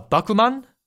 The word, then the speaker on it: Bakuman: The titles, in case people were thrown off by Katie's pronunciation, are Bakuman, Sono Biskudoru wa koi suru, and Eizouken ni wa te dasuna.